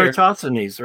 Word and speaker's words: Eratosthenes: Eratosthenes, right? – Thank you. That's how you pronounce it.